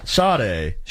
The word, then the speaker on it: Sade: Now, how do you say her name? – Sade? – Yeah, there's no R in there. – Oh, okay. – I heard one DJ once call her Sadie.